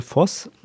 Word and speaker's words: Voss: Hat er irgendwie den Dr. Axel Voss, hat er nicht mit weichem S, sondern mit hartem S ausgesprochen. Also er hat ihn dann Dr. Axel Fotz genannt und ich glaube, die Süddeutsche hat es auch übernommen.